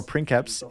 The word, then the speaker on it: princeps: These houses are led by princeps or princeps, if you use the Latin pronunciation, and below him are barons which can hold a variety of titles.